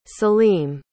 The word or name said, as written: saleem